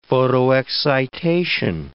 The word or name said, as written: photoexcitation